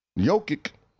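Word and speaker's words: Jokić: But hey, man, Jokic. That's how you say his name.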